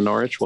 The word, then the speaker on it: Norwich: No W on the Norwich. Well done.